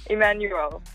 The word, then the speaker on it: Emmanuelle: Emmanuelle.